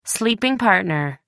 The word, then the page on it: sleeping partner: American English